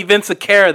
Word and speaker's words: Vinciquerra: Yeah, Tony Vinciquerra, the head of Sony Pictures.